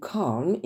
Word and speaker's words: calm: You can't remain calm in such a situation. Je vous rappelle que le L de calm ne se prononce pas.